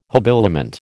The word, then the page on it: habiliment: American English